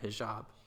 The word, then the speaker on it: hijab: Tell me, what does a hijab for, am I saying that right? Hijab. – Hijab, yeah.